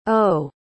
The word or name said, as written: eaux